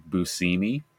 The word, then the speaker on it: Buscemi: Like when you realize that it's actually pronounced Steve Buscemi.